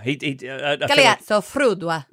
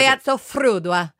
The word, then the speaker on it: Galeazzo Frudua: How do you pronounce his last name again? – Frudua. Frudua. – Galeazzo Frudua. – Yeah, that's how he says it. – Galeazzo Frudua. – Yeah. – You have to roll your R's.